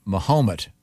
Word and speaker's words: Mahomet: And then on the 17th at Yellow & Company, and I pronounce it Mahomet, Illinois, and their team, the Fighting Ali's.